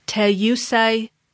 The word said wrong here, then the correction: Tayasui: Oh, Tayasui sketches.